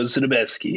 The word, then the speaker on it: Zubetski: It's Polish, so Zubetski.